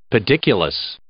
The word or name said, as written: pediculous